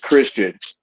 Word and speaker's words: Xtian: So, you know, what I can let the fans know is by the end of the night, you're going to know that X-T-I-A-N is pronounced Christian.